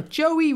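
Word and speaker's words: Joie: No. Joey Ruth Armstrong is probably correct, no? – You got that. The Joey bit. – It's J-O-I-E, so...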